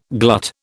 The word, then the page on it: glut: British English